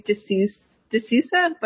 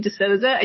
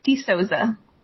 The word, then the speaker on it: De Sousa: Most people say D'Sousa, but it's actually D'Sousa, and you got really close.